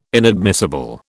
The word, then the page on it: inadmissible: British English